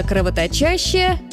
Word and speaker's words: кровоточащая: Ну и, наконец, стишок не больно складный, но написанный специально для того, чтобы запомнить ударение. У актера на сцене рана кровоточащая, зритель знает – рана не настоящая.